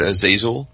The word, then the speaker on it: Azazel: By the way, for the record, Azazel, according to Merriam-Webster, rhymes with appraisal and witch hazel.